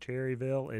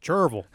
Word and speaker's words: Cherryville: If you're just moving to the Carolinas, that is a nuance to note right away, just the same as Cherryville is not pronounced Cherryville. It is Cherville.